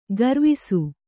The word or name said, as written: ಗರುವಿಸು